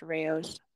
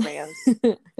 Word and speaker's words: Rao's: And yes, friends, it is pronounced Rao's. Much to our chagrin. It looks like it's pronounced Rao's, but it's Rao's.